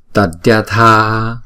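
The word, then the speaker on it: Tadyatha: Chữ này chúng ta đọc là Tadya Tha Tadya Tadya. Chữ này là giống như là Diya ha, nhưng mà chúng ta đọc là Dya Dya Tadya Tadya Tha không?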